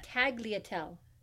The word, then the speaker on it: tagliatelle: Tagliatelle.